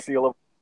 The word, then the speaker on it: Seal: Like I like the tagline for the how do you pronounce the publishing company? Is it Seal? – Seal. – Okay. – Yeah. – Okay. – Like Seal.